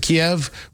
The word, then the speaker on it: Kyiv: The distance from St. Petersburg, Russia, to Kyiv, and we call it Kyiv because that would be the Ukrainian pronunciation as opposed to Kiev, which would be the Russian pronunciation.